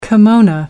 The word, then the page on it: kimono: American English